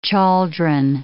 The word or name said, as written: chaldron